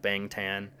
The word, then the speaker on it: Bangtan: And Bangtan is B-A-N-G-T-A-N. So it's spelled like Bangtan, but it's pronounced Bangtan.